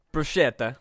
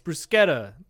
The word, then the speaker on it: bruschetta: Bruschetta. – Wrong. It's bruschetta. They don't say sh.